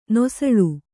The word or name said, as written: ನುಸುಳು